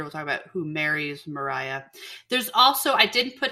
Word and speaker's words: Maria: Mariah is her name, but it's spelt like Maria.